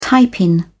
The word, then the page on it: tiepin: British English